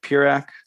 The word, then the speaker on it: Peurach: Follow me on Twitter at Greg Peurach. Last name is spelled P-E-U-R-A-C-H.